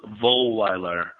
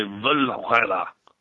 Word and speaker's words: Wohlweiler: It's Wohlweiler, or in German you would say Wohlweiler.